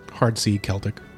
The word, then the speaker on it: Celtic: Hard C, Celtic. – Yeah. Celtic? Celtic. – Celtics are basketballers. – Yeah, but they're supposed to be the same thing, right? No, it's just a mispronunciation. It's an Americanization.